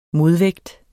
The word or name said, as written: modvægt